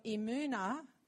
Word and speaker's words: emunah: the familiar word, and the word is actually, I'm going to see if I can say it right, emunah, E-M-U-N-A-H, but you say the mu in the middle, emunah. And E-M-U-N actually is also from where the word amen comes from, emun, amen.